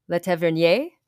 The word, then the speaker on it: Le Tavernier: The chief authors of this publication are Thomas Robert, Ellie Tang, and Emmanuel Le Tavernier, I did not say that right. Le Tavernier, probably, because they have French, yeah. So Emmanuel Le Tavernier is probably correct.